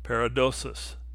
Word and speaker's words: paradosis: Tradition comes from the Greek word paradosis. It rhymes with paralysis and means a giving up.